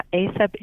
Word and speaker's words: ACEP: ACEP, I assume that's the way you pronounce it, and what that actually stands for. – Yeah, ACEP is the way you pronounce it. It is ACEP, and that stands for Agricultural Conservation Easement Program.